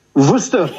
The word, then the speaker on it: Worcester: You're not pronouncing it right. Come on. – Do you have to say it? Worcester. – Worcester. – Quite right, Worcester.